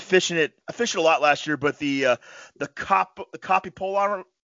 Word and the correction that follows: copolymer: am I saying it right? – Copolymer. – Copolymer line.